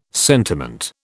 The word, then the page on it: sentiment: British English